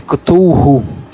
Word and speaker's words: Cthulhu: Na verdade, a pronúncia seria Cthulhu.